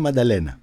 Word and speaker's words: Μανταλένα: Θάνος Πολύδωρας σε μια διασκευή του τραγουδιού Μαντελένα. Στα ελληνικά είναι Μανταλένα. Φαντάζομαι.